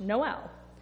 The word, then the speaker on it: Nowell: The correct spelling is N-O-W-E-L-L, still pronounced Noel, which probably accounts for the fact that somewhere along the line it got misspelled in the first place.